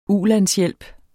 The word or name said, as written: ulandshjælp